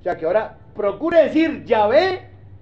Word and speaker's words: Yahvé: Pero tal parece que la pronunciación más correcta no es Jehová, sino Yahvé. Así que en adelante, nada de Jehová.